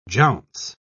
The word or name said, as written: jounce